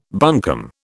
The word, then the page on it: buncombe: British English